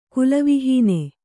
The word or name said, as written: ಕುಲವಿಹೀನ